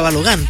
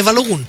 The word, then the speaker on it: Balogun: muy mal le decía Balogun, porque yo creí que así se pronunciaba, digamos, es norteamericano, entonces termina en U, debe ser Balogun, no, es Balogun.